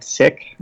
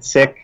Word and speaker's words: Sikh: And I should stop right there and sort of say, while many call it Sikh, it is actually, in fact, Sikh. Okay. So you'll hear me pronounce it Sikh from here forward.